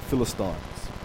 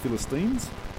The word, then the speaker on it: Philistines: And he's been sent by his father to get food to his brothers on the battlefield because the Israelites are fighting the Philistines, which is the correct pronunciation, not like the Americans say Philistines. There's a Stein in there.